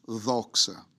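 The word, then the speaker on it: doxa (δόξα): In Greek, the word glory is the word doxa, or the right pronunciation is doxa, and it's D-O-X-O, doxa, and it means all that God has, all that God was, and all that God is.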